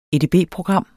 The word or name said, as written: edb-program